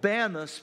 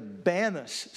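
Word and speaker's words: Banias: Today it's called Banus because the Muslims don't have the letter P in their alphabet, their pronunciation, and so they changed it to Banus.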